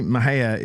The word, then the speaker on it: Mexia: She was from Mahia, Texas. Anna Nicole Smith. – Oh, really? – You guys remember her? She was from Mahia. So there's this big time. So you see Mahia is spelled Mexia, right?